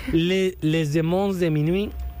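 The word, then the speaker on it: Les Démons de minuit: Chécate que hasta la pronunciación, la nasalación de le francés. Les Demons de Minuit.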